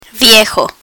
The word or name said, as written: viejo